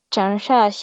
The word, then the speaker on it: Changsha: Chan Cha, perdón por la pronunciación, pero en chino es Changsha Entonces, si lo dije mal, pues ahí díganme cómo se dice.